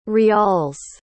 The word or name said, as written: rials